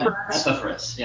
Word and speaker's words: Epaphras: Epaphras, yeah.